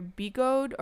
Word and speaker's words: Bigode: But it's spelled B-I-G-O-D-E, and it was either Bigode or Bigode.